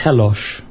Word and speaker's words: Tellos: This went on to very shortly after that, I started to meet my space brother, whose name is Telosh, pronounced Telosh, but it's spelled T-E-L-L-O-S.